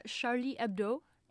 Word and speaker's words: Charlie Hebdo: Charlie Hebdo.